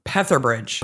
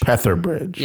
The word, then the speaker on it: Petherbridge: He's played by Edward Petherbridge. – Petherbridge. – Petherbridge.